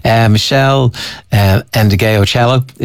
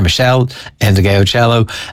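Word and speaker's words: Meshell Ndegeocello: Michelle Endegeocello is how you say it. If you take a run at it, it's easier. Michelle Endegeocello.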